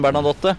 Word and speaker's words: Bernadotte: Selv Bernadotte han, er det mange som sier.